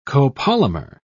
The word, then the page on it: copolymer: British English